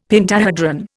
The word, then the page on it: pentahedron: Indian